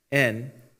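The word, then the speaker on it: in (ἐν): which is the Greek word epsilon ni, in, pronounced the same as the English in, right?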